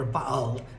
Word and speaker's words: Baal: Elijah goes, "I'm the only one that hasn't bowed to Baal," or Baal, as I found out it's supposed to be pronounced.